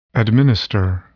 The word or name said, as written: administer